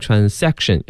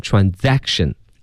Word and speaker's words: transaction: 那这里呢，朋友们注意啊，那个 trans 后面啊，那个 s 要读成的是 z 它的音，所以不要读成 transaction，要读成 transaction。